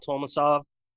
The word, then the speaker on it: Tolmasov: I'm going to butcher your last name, so why don't you just go ahead and introduce yourself. – Kyle Tolmasov.